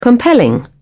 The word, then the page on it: compelling: British English